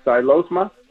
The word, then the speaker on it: Xylosma: Got a question about a, let's see if I pronounced this, Xylosma? – Yeah, perfect.